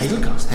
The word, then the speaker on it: Hazelcast: Jak se to vyslovuje? Hazelcast? Hazelcast. Hazelcast. Je to vlastně od toho slova Hazel eh, jako lískový oříšek.